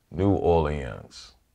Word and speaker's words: New Orleans: If you think of the French rhythm, Orléans. – Yeah. – We have the French rhythm without the French sound. New Orleans. So that's the way to think of it.